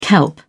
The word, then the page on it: kelp: British English